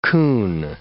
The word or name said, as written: coon